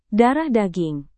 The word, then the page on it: darah daging: Indonesian